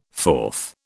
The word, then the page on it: fourth: British English